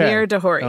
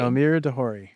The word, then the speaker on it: Elmyr de Hory: I'm like, I realized that that's not how you say his name. – Okay. – Elmyr de Hory. – Elmyr de Hory.